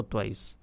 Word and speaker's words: Twice: Déjeme revisar aquí en Google, a ver si estoy pronunciándolo bien, pues ya me generó la duda, mi hermano, si se dice Twist o Twice. Vamos a ver aquí. Vamos, ¿cómo se dice? ¿Ah? Vamos a ver por acá, apreté algo que no era. – Twice. – Twice. – Twice. – Ah, muy bien.